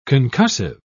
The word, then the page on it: concussive: British English